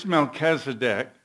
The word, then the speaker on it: Melchizedek: In chapter 7, it says, for this Melchizedek, that's how I'm going to pronounce it. There's a couple different ways of pronouncing it.